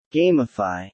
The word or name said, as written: gamify